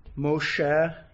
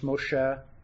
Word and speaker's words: Moshe: Moses, or Moshe, as it would be pronounced Moshe, and it literally means drawn out.